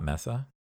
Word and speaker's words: Mesa: Is it Mesa, not Mesa? – Mesa. – Mesa. Saying it wrong this whole time.